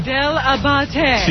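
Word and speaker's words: Dell'Abate: See Gary Diabiti at Christmas Night Spot. See Gary Diabiti. How do you spell it? How say his last name? – Del Abate. – See Gary Del Abate at Chemistry Night Spot in Manayunk, Pennsylvania, this Friday from 7 to 9.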